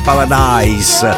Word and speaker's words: Paradis: Si sbaglia in molti, perché alcuni la pronunciano in inglese paradise, alcuni in italiano paradis, ma in francese bisogna dirlo. Lei si chiama Vanessa Paradis. E il brano è Je le Taxi, l'abbiamo ascoltato quest'oggi, in questo sabato. Sapete che i francesi è diverso dall'inglese, l'italiano, alcuni la pronunciano in una maniera o in un'altra, io l'ho pronunciata in tutte e due, ma invece mi hanno detto che si dice Paradis, perciò Vanessa Paradis era bellissima da giovane, bellissima tuttora.